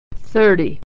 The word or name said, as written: thirty